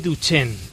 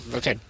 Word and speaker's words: Duchenne: Bueno, hay que decir que tiene una enfermedad, que es la enfermedad que se llama distrofia muscular de Duchenne. Lo he dicho bien, ¿verdad? – Lo has dicho bien. Sí, nombre es raro, pero Duchenne.